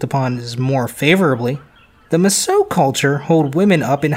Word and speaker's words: Mosuo: The Masao, and that's spelled M-O-S-U-O, make up one of the 56 minority groups in China.